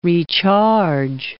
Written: recharge

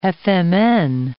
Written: FMN

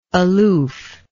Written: aloofness

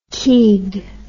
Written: keed

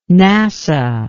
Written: nasa

